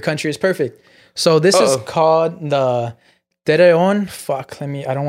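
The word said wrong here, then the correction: Torreón: Torreon massacre.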